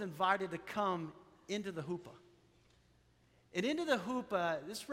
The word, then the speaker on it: chuppah: In Jewish weddings, they have what's called a huppah. And this is a huppah right here. We have another one on the other side. It's spelled C-H-U-P-P-A-H. But a huppah is something at every Jewish wedding.